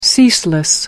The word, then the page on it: ceaseless: American English